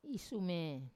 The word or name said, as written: isume